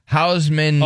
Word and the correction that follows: Houshmandzadeh: Oh, Houshmandzadeh. – There you go. Boom. – Houshmandzadeh.